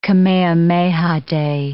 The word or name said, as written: Kamehameha Day